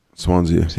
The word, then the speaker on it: Swansea: I'm assuming you pronounce it Swansea, since that's what you keep calling it, and I don't have the article. – Swansea, yeah.